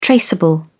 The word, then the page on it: traceable: British English